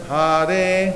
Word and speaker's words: Hare: Pronounce each name distinctly. Hare Krishna Rama. It says each name is two syllables. Hare. I'm going to repeat that. Hare.